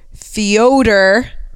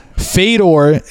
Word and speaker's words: Fyodor: Bellator had a card in Moscow with Fyodor. That's how you're supposed to pronounce his name. – I know. But. – And you just do whatever the fuck you want, like everyone else? – Fedor is a legend.